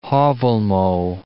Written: Haavelmo

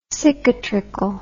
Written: cicatricle

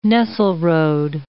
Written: Nesselrode

